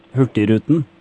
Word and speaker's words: Hurtigruten: Hurtigruten.